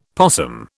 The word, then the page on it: possum: British English